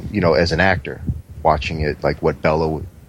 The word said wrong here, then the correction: Bela: is it Bella or Bela? – Bela. – Oh, so I've been saying his name wrong, like, like I don't think, like, he minds.